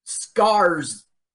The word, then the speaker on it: Skarsgård: Isn't he a Slosgard? – Yeah, I can't even say his name. S-T-E-L-L-A-N. Stellan. – Stellan. All right. – S-K-A-R-S-G-A-R-D. Skarsgård.